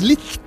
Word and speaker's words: Liszt: Que hay mucha gente que lo pronuncia mal, pronuncia list. Es difícil. – Acabado en Z y es list. Acabado en T, no es list. Es list.